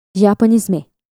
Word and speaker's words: Japanisme: This term was created in France, pronounced Japanisme.